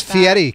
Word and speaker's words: Fieri: And it's Fieri, Kennedy.